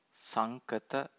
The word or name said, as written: sankata